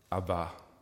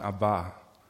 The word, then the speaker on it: Abba: Ähm, Jesus hat uns gesagt, wir sollen zu Gott sagen, Abba. Betonung auf der letzten Silbe, ganz wichtig, nicht die schwedische, schwäbische Popband, das ist auch nicht, die, nicht die schwedische Popband, sondern Abba, unser Vater.